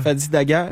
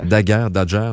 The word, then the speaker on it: Fadi Dagher: On le prononce-tu comme ça, Dagher? – Fadi Dagher. – Dagher.